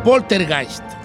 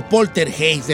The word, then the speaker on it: poltergeist: La palabra poltergeist o poltergeist, depende cómo lo quiera usted pronunciar, es una palabra alemana que significa polter, que es ruido y Geist, que es espíritu.